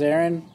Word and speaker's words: Aaron: And so it finally got to me, and I said, my name's Aaron, and I am without child, and I am barren. – Oh, my God. – Barren, which rhymes with Aaron? – Yeah, that's true.